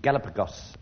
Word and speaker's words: Galapagos: It was considered long extinct in the Galapagos.